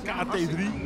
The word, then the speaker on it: KT3: Het is niet mijn eigen tandarts, maar KT3 Dental Clinic, toch? Zeg ik dat goed? – KT3. Ja, geweldig.